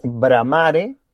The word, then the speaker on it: Bramare: The exact word is Bramare. It sounds like that in Spanish, Uco Valle Malbec.